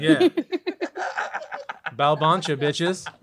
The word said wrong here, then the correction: Bulbancha: All right, to give it to me one more time. Bulbancha. – Bulbancha. – There we go.